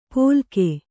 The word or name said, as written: ಪೊಲ್ಗೆ